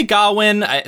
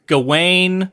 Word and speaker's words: Gawain: But Sir Gawain, which I say Gawain, sometimes it's Gawain. I've heard it pronounced different ways. I think in the movie they pronounce it different ways. – In the beginning, they called him Garwin.